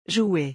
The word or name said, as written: jouer